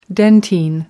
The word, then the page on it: dentine: American English